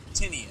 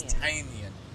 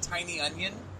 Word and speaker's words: Tynion: I'm always going to say his name like that, because I learned listening to the First Issue Club that his name is not pronounced Tynion. As it looks, it's Tynion. – Tynion. – Like tiny onion? – Oh, that's horrible.